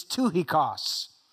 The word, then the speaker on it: Tychicus: His name was Tychicus. Actually, I looked it up today. The proper pronunciation is Tychicus. I'm not going to say it that way. I've said it Tychicus my whole life.